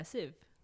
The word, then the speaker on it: sieve: No, sieve. – S-I-E-V-E? – Yeah, sieve.